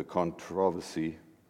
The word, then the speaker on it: controversy: I was intrigued going back, because it's been many, many years since I dealt with the controversy, that's the British way of pronouncing the word, of The Last Temptation of Christ.